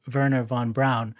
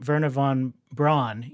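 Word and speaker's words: Wernher von Braun: Werner von Braun, um, who, just like every movie ever made about space, they pronounce his name Werner von Braun, even though it was always Werner von Braun, right?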